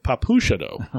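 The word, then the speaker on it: Papushado: Navit Papachado, guarantee that's not right. – Papachado. – Papuchado. – Okay, that's right.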